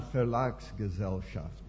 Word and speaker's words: Verlagsgesellschaft: Und dass ich eigentlich zusammenarbeiten darf mit dieser Verlagsgesellschaft in Dillenburg. – Yeah, Verlagsgesellschaft. That's the way to pronounce it.